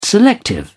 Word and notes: selective: British English

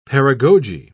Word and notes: paragoge: British English

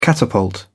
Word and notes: catapult: British English

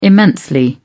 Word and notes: immensely: British English

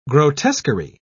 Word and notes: grotesquerie: British English

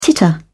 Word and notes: titter: British English

